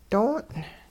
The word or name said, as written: doong'